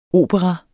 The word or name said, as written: OPERAERNES